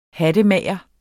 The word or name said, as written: hattemager